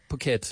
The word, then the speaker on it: Phuket: It rhymes with bucket. Phuket.